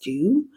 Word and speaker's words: Doux: And so I use, I believe it's pronounced dew, Mousse Def Texture Foam. And you can download the full guide that has all the products in it. So if I'm not pronouncing that right, it's spelled D-O-U-X and it's French.